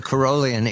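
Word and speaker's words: Caroline: The first thing, Catherine, I have to learn is to say Carolian. You've already given me a lesson of how to say it correctly. This is the Carolian era.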